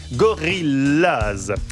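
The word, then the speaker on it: Gorillas: Gorilla se prononce comme en anglais. Gorillaz.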